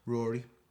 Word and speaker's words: Ruairi: This is from Ru- Ruairi Robinson, uh, the director. Rory. Rory. – Yeah. That's a w- strange spelling of Rory. I think that's Irish. That's how the Irish spell it.